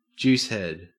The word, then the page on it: juicehead: Australian English